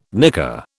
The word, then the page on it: nicker: British English